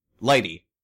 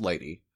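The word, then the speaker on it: lighttpd: How's that pronounced? Lighty. Yep. Lighty. It's pronounced Lighty.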